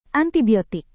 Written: antibiotik

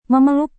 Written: memeluk

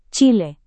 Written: chile

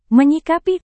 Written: menyikapi

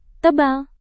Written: tebal

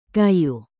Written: kayu

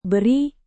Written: beri

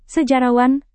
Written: sejarawan

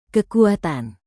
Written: kekuatan

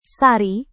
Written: sari

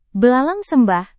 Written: belalang sembah

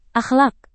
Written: akhlak

